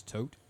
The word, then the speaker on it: Thoth: It's actually spelled Thoth, but I've always heard the correct pronunciation of that is Tote.